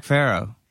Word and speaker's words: Farrow: Okay, here we are with Zach Farrow. Did I pronounce that right? Faro? – Yeah, Farrow. That's Farrow? Right. Okay. Yeah, like the Egyptians, only spelled different.